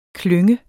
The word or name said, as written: klynge